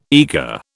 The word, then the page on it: eager: British English